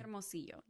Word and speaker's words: Hermosillo: Hermosillo. Mm-hmm. Am I pronouncing it correctly? – Hermosillo, yes.